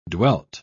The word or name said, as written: dwelt